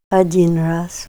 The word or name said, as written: ad'in ras